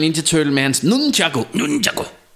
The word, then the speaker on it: nunchaku: om nunchakos. Og jeg siger jo, som den danske er sådan, nunchako. Og han siger, ah, nunchaku. Nunchaku. Så i Japan udtaler man det, nunchaku.